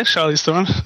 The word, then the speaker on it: Charlize Theron: Yeah, Charlize Theron.